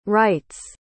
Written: wrights

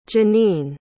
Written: Janene

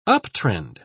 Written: uptrend

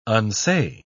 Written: unsay

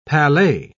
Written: palais

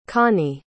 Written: connie

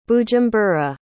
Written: bujumbura

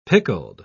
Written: pickled

